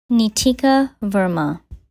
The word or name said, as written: Neetika Verma